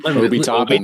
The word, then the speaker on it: Obi Toppin: Tell me about tell me if I pronounce this correctly, but tell me about Obi Toppin. – Obi Toppin. – Obi Toppin, that's right.